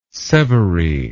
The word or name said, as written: severy